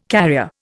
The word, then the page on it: carrier: Indian